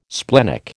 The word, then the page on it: splenic: American English